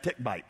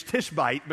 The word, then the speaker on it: Tishbite: When I was a kid, we always pronounced that tick-bite. When I was in middle school, we translated that as tush-bite, but neither of those is correct, all right? It's Tishbite because he was from Tishba in Gilead.